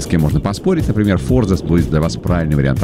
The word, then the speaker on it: форзац: Так вот, действительно, форзац считается правильным ударением. Но, вы знаете, словари сейчас спорят. Некоторые, те, что ратуют за произношение для комментаторов, для дикторов, для всех СМИ, они говорят, что форзац — это правильный вариант ответа.